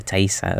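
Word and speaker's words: Taissa: So I checked the pronunciation on, it's Thaisa Farmiga. – Oh, Thaisa. Okay.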